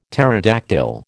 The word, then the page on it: pterodactyl: American English